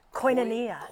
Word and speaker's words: koinonia: Koinonia. Most commonly pronounced koinonia.